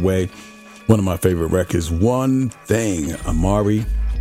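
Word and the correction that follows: Amerie: Somebody told me I said the name. I say her name wrong, but. – A. Marie.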